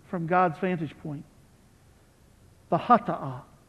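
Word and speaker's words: Hata'ah (חטאה): Now, in the Hebrew worship custom, there is what's called the, let me spell it for you first, the C-H-A-T-A-A-H, Hata'ah, Hata'ah. The Hata'ah means the sin offering.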